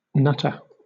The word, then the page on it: nutter: British English